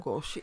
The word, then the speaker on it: Лугоши: Лугоши.